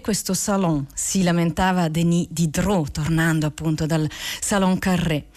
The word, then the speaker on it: Diderot: Denis Diderot, che noi italiani molto spesso chiamiamo Diderot, ma in realtà si pronuncia Diderot,